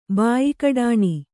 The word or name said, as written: ಬಾಯಿಕಡಾಣಿ